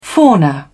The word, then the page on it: fauna: British English